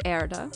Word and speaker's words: Erde: In German, of course, it's Erde.